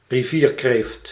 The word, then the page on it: rivierkreeft: /riˈviːr.kreːft/